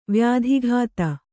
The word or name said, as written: ವ್ಯಾಧಿಘಾತ